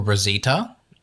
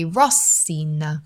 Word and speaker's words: Rosina: I think in the Italian pronunciation it would be Rosina. – Rosina. – Yeah, like the first part of the word would be the inflection, I guess.